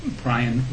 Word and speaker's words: Brian: Brian Robert Leach. Brian, B-R-I-A-N, Robert, R-O-B-E-R-T, Leach, L-E-A-C-H, and my title is the Chief Risk Officer of Citigroup.